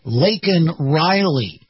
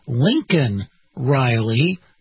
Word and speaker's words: Laken Riley: And he's supposed to say Laken Riley. But in reality, he ended up saying, calling her Lincoln Riley.